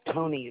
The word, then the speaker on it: Thonney: Last name is Tony. It's spelled T-H-O-N-N-E-Y.